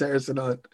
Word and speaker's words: Harrison Hunt: There's an E at the end of the name, right? – Yeah, there is, but it's Harrison Hunt. The E is silent.